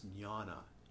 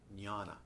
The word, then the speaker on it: jnana: The word they're using for wisdom is jnana, J, Spanish A, A-N-A, jnana, long A-N-A, jnana.